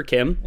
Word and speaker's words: CHIM: I pronounced them Kimmer earlier because I looked up the pronunciation for Chim, all caps, and one of the devs came out in a forum post and said it's pronounced Kim.